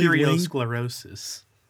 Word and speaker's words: arteriosclerosis: She also had a coronary thrombosis, which is blood clots, and arteriosoriasis, which is thickening, hardening, and loss of elasticity of the walls of the arteries. Most likely arteriosclerosis.